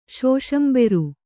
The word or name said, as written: ಶೋಷಂಬೆರು